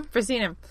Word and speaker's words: proscenium: Did I say the word right? – Proscenium? – Proscenium. – No, you did. You did say it correctly.